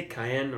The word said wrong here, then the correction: Caen: or whatever? – Caen.